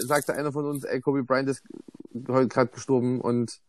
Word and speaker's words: Kobe Bryant: Kobe Bryant.